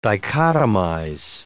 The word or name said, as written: dichotomize